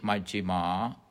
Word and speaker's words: Majjhima: The middle path, Majjhima Patipada, M-A-J-J-H-I-M-A, Majjhima, long A, Majjhima, then Patipada, P-A-T-I, diacritical mark, dot underneath T, pa, T, I, P-A-D-A, Patipada, Majjhima, Patipada, the middle path.